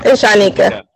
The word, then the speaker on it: Shynikia: Yeah, is that how I say it? That is It's Shynikia.